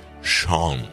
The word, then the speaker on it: Sean: Porque en la final contra Francia, mientras escuchamos Beautiful Boy de Lennon, tema dedicado a John, a su hijo, no es sin, por favor, es John, la versión escocesa de Juan. Sean, se pronuncia Sean, como Sean Connery.